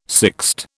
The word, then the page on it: sixte: British English